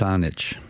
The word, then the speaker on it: Saanich: Now, how badly did I just pronounce the town where you live? – Saanich. – Saanich. – Yeah, it's an Indian word, from the Coast Salish people.